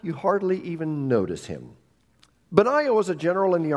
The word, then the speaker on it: Benaiah: Today we're going to look at a man named Benaiah. Some pronounce his name Benaiah.